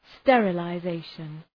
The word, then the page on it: sterilization: ,sterələ’zeıʃən